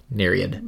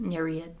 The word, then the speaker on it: Nereid: Nereid. – Nereid. – Yep. Yeah. You know, we pronounce all of the vowels in Greek.